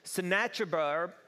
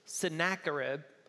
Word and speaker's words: Sennacherib: Sennacherib, Sennacherib. That's how you pronounce it.